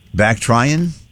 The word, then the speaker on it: Bactrian: A camel with two humps is called, and I never heard this before, it's spelled B-A-C-T-R-I-A-N. Bactrian? A camel with two humps is called a Bactrian.